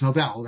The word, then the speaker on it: Nobel: I said Nobel. It's Nobel. That's how you pronounce it.